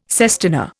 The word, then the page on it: sestina: Indian